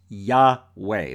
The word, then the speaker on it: Yahweh: the name that's originally here in Hebrew was Yahweh. That's the best English pronunciation that I can come across.